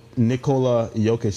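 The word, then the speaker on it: Nikola Jokić: But my the Joker, Nikola Jokic.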